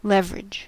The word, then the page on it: leverage: American English